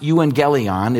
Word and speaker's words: euangelion: It's pronounced euangelion. It's where we get our word evangelism.